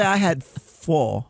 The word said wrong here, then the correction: pho: How do you say it? – What? – Have you ever heard pho. – It? It's a noodle. – You had pho. – I had pho.